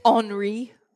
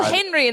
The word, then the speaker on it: Henri: I believe it's Henri. – Oh, what am I? – Really? – I say it how it's spelled. – Oh, yeah, same. I look at like, oh, Henry, and then I hear the news reporter's like, Henri.